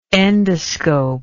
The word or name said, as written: endoscopist